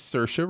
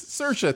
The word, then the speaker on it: Saoirse: She was like, "It's Saoirse Ronan." I was like, "Yes, Saoirse, thank you. I wondered about that."